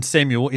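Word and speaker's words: Samual: Rather than Samuel, S-A-M-U-E-L, S-A-M-U-A-L.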